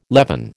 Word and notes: leaven: American English

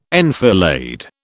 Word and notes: enfilade: British English

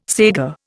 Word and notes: saiga: Indian

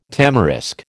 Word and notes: tamarisk: American English

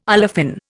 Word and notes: olefin: Indian

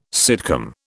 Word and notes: sitcom: British English

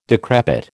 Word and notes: decrepit: American English

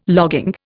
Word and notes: logging: Indian

